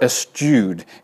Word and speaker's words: eschew: But interestingly, if you read my thing, the online, I want to say onychological, but that's not, etymological dictionary, this eschew comes from a word skew, S-K-E-W. So that's why some think, if you just were to take the word skew, you'd say skew. That's why some will pronounce it as skewed or eschewed. Either is probably correct. It really doesn't matter.